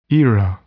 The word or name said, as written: era